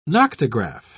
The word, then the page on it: noctograph: British English